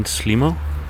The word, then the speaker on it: Slimo: Walking down a quiet street now, and you just heard the song Teddy, Saveru, by Danish band Slimo. They spell it S-L-I-M and then a zero, but they usually pronounce it Slimo.